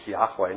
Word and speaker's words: Yahweh: And that's why we believe that the name for God is Yahweh, not Jahweh or Jehovah, because the Hebrews didn't have a J, hard J sound.